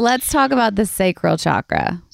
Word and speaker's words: chakra: Is it chakra or chakra? I can't say it. – You know, the traditional like pronunciation is chakra. – Chakra. – But you hear everyone in you know, the West these days saying chakras.